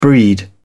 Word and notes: breed: British English

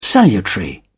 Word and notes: salutary: British English

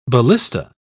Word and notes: ballista: British English

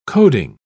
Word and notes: coding: British English

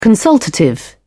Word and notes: consultative: British English

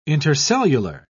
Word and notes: intercellular: British English

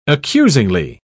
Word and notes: accusingly: British English

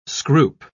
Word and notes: scroop: British English

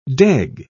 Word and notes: deg: British English

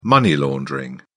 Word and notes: money laundering: British English

